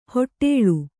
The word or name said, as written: ಹಟ್ಟಳೆ